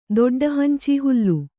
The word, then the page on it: ದೊಡ್ಡಹಂಚೀಹುಲ್ಲು: doḍḍa hancī hullu